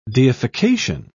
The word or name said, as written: deification